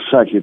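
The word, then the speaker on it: Psaki: Именно так ее фамилия Саки, там Пини читается, что самое правильное. Они ее так произносят, как минимум.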